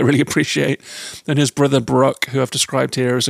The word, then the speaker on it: Brokk: And you do meet Sindri and Brokk, or Brokk, if you're pronouncing it as an "ur," these wonderful dwarf brothers that you meet throughout your adventure, who are blacksmiths and real characters.